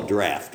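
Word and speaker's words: draught: By the way, that word there, draft, I hear a lot of people struggle with how do you pronounce it. If you went to an old English advertisement for a pub, it would talk about draft beer, and that's how they'd spell draft right there, okay? It's like the word laugh, all right? It uses that pronunciation.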